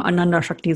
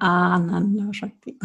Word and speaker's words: Ananda Shakti: Ich muss mich immer, ich komme aus dem Ruhrpott, ich möchte mal Ananda Shakti sagen. Aber das heißt, das wäre die, das Elends, äh, die Elendsenergie, wenn ich das, wenn man es so ausspricht, wie ich es jetzt ausgesprochen habe. Aber ich meine Ananda Shakti.